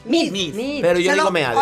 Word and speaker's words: Meade: Oye, se pronuncia Mid, entonces ya todo el mundo pronunciaba el lapido correcto.